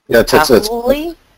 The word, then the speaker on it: Kahului: It's Kahului? What is Kahului? It? – Yeah, K-A-H-U-L-U-I. – Yeah, Kahului or something like that.